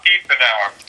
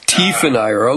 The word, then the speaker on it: Tiefenauer: Bob Typhon, or I mean, I had his baseball card, but I don't think I don't think I ever was able to pronounce his name correctly. Bob Bob Tiefenauer. Tiefenauer, okay.